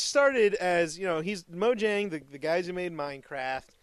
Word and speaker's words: Mojang: Mojang.